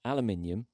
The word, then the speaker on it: aluminium: Now, this has been spelled aluminum, but we'll say it correctly. Aluminium, ceramic, glass, or lucite? – I think it was lucite.